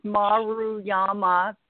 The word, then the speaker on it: Maruyama: And I'll, like the last time, it's two syllables at a time. It's Maruyama, big tongue twister.